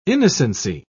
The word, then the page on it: innocency: British English